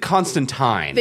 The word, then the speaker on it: Constantine: I believe they did Constantine. – They did do Tyne. – Yeah, they did do Tyne. – Because, I mean, this is a British show, so the British say it as Tyne.